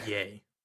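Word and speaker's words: Ye: Things pronounced yay. – Whatever. – As in Kanye.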